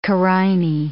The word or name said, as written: carinae